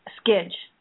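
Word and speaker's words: Scidge: Skidge.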